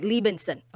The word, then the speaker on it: Liebensohn: It's pronounced Liebensohn. The H is silent. – Okay, Liebensohn.